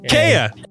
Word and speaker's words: Kea: It's K-E-A. I think it's K. – Did you say K-E-Y? – No, it's K-E-A. – Kea. – Oh, yeah. – Kea.